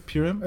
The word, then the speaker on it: Purim: and you made it very easy for me to understand what Purim is. – I hope so. – Am I saying it right, Purim? – It's Purim, but yeah, you can...